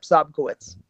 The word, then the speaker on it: Sobkowicz: Is it, I'm going to see, Oscar Sobkowitz? – Yeah. – Did I say it right? – Yep, Sobkowitz.